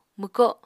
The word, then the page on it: mwko: Apatani